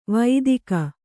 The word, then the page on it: ವೈದಿಕ: Kannada